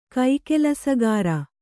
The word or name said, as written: ಕೈಕೆಲಸಗಾರ